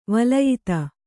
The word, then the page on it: ವಲಯಿತ: valayita